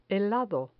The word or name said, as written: helado